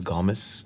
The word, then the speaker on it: Gomes: Well, the correct pronunciation is Gomes. – This is Ben Gomes. – But I say Gomes.